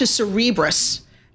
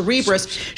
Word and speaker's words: Cerebras: Let's move on to Cerebras. Because is that how you pronounce it? – It is. We checked it out. We called. – Thank you. – How do you like that? Call the company. How do you say your name? Like Cerebral. Yes. So Cerebras shares soaring.